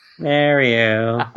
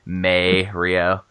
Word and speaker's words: Mario: There was something that I read of I think Nintendo was calling out to fans that were mispronouncing Mario, doing May Rio. – Yeah. – In May, in the month of May.